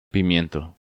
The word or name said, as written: Pimiento